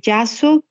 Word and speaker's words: JASSO: Muy bien, entonces la página sugerida es de Yaso, que se escribe J-A-S-S-O.